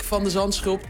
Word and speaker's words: van de Zandschulp: Maar we gaan het hebben over Botik van de Zandschulp. Tennissensatie. – Van der Zandschulp, wat we zo moeilijk in het buitenland kunnen uitspreken. – Van de Zandschulp. – Ja, precies.